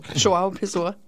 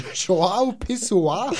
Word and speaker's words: João Pessoa: João Pessoa. – João Pessoa. – João Pessoa.